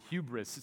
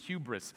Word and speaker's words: hubris: It's a Greek word, hubris. It's almost pronounced the exact same way that we pronounce it in English, hubris.